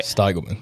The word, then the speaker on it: Steigelman: Steigelman, but Steigelman. – Close. – I was really close. The I is long.